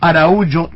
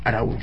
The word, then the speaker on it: Araújo: quien se llama Agenor de Miranda Araújo Neto. Me gustó la pronunciación, Araújo. – Sí, Araújo. – Acá es Araújo, en Brasil, sí.